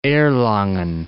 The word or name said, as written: Erlangen